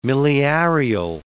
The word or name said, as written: miliarial